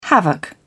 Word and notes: havoc: British English

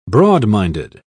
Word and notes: broadminded: British English

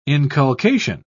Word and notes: inculcation: British English

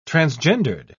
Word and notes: transgendered: British English

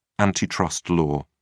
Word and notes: antitrust law: British English

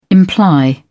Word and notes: imply: British English

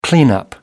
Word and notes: cleanup: British English

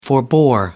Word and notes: forbore: American English

